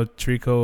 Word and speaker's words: tricot: So we decided to go to San Antonio, and Trico was in town. And if you don't know Trico or Tricot, whatever they're actually called, last time I saw them, they pronounced it as Trico.